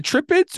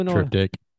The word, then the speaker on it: Triptych: Is it Trippets or is it Triptych? I don't know. – Triptych.